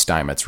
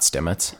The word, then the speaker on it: Steinmetz: It's pronounced Steinmetz, right? Now it's not Stimmets.